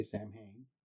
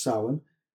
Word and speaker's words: Samhain: In America, they say Samhain. Wrong. But it's Samhain. From Irish Celtic points of view.